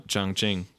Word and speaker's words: Chongqing: How do you say it? Chongqing? Sean Kingston? – Chongqing.